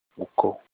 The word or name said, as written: uko